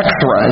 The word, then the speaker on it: echthra: The word enmity in the Greek is ekthra. It's spelled E-C-H-T-H-R-A.